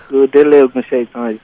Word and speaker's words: Oladele Ogunseitan: My guest is UCI Professor Oladele Ogunseitan. Did I get that pronunciation right? – You did. Everyone calls me Deli, so that's Oladele Ogunseitan.